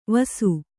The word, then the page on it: ವಸು: vasu